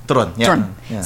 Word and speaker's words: Turon: Turon? – Turon.